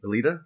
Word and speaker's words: Alita: We can start with the Alita. Is that how you say it? – Yeah.